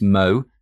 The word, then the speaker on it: Mao: Firstly, we have Samson Mao. It's actually pronounced Mo, so do remember that if you're doing an interview with him.